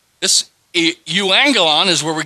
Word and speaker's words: euangelion: This is the Greek word euangelion.